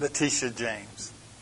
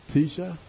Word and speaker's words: Letitia: Letitia James. – Tisha? – Letitia. – Letitia. No way. Uh, no. Uh, okay. Letitia James.